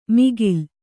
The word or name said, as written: ಮಿಗಿಲ್